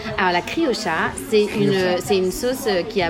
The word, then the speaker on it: criolla: Avec 2 sauces, donc la griola et la chimichurri. – Alors, faut savoir que les Argentins prononcent le che et pas le ye, donc on dit la criocha.